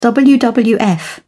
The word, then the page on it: wwf: British English